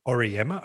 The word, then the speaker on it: Auriemma: John Wooden, Mark French, Kathy Oliver, Gino Auriemma.